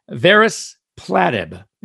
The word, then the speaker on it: Varespladib: It's called Varis. Platib, V-A-R-E-S-P-L-A-D-I-B, Varis Platib, and it neutralizes venom in snakes.